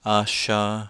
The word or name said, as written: asha